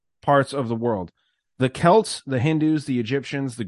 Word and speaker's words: Celts: I gotta interrupt and say thank you for pronouncing Celts correctly. – Yeah, what do people say? Celts? – Celts. Because it's the Celtics. Celtics, but thank you.